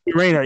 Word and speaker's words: Renner: Renner.